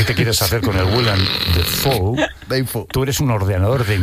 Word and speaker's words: Willem Dafoe: Willem Dafoe.